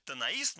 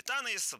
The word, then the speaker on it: Танаис: Дело в том, что 1 августа 1961 года, то есть практически 65 лет назад, первых посетителей принял музей-заповедник Танаис, ну или Танаис, двойное ударение.